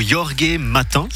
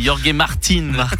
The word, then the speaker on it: Jorge Martin: En MotoGP, c'est le titre pour Jorge Martin. – Ça, c'est très bien prononcé. Jorge Martin.